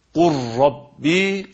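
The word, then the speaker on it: kur rabbi (قل رب): Bu kelimelerin okunuşu da kur rabbi şeklindedir.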